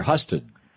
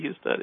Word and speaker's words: Husted: So is it Husted or Husted? – I've heard it pronounced both ways, mostly Husted, yeah.